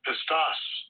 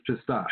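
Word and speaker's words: pistos (πιστός): Strong's G, 4103, pistos. – Yeah, it's pistos.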